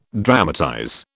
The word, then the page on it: dramatize: British English